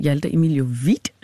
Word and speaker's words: Hjalte Emilio Witt: Du er i studiet med Christiane Vejlø, og sammen med mig har jeg i dag Hjalte Emilio Witt. – Hej. – Du griner hver gang, jeg udtaler det, så jeg bliver sådan i tvivl om jeg siger det rigtigt, men... – Det er rigtig godt.